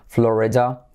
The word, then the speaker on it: Florida: Florida en español, en inglés, Florida. Porque tenemos la letra clave L, flo, re, letra clave R, re, y como E, flore, da. Esa D se puede hacer como D o también, al hacerlo rápido, lo hacemos como una R suave, letra semiclave.